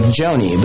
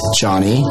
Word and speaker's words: Jony: Example, my name is Johnny, spelled J O N Y. And it's normal for someone to pronounce my name like Joni.